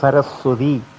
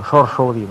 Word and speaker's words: സരസ്വതി: ഇപ്പം സരസ്വതി എന്നൊരു പേര് തന്നെ നമ്മൾ എത്ര ഭംഗിയായിട്ട് സരസ്വതി എന്ന് പറഞ്ഞാലും അവർക്ക് മനസ്സിലാവില്ല, ഷോർഷോതി എന്ന് പറയണം, മനസ്സിലായി. അപ്പോൾ അതുകൊണ്ട് അപ്പോൾ കുറേ നേരം ഒരു അങ്ങനത്തെ സന്ദർഭം ഉണ്ടായി. ഇപ്പോൾ സരസ്വതിക്കും അദ്ദേഹത്തിൻ്റെ പത്നിക്കും സരസ്വതി എന്ന് പറഞ്ഞിട്ട് മനസ്സിലായില്ല.